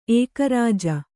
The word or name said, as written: ಏಕರಾಜ